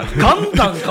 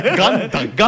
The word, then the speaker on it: 岩男: ガンダンかもしれない。 – ガンダン。 – ガンダン。岩男と書いてガンダン。